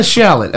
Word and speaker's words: Shalott: Lady and the Shalott. Am I pronouncing that right? – You're absolutely pronouncing it right.